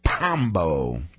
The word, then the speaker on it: Pombo: Well, you could pronounce that right. – Pombo. It sounds like Bombo.